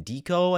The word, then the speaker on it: Diko: I've been looking at Alex is the ticker, and then Dico, I think is what it's pronounced, D-I-K-O.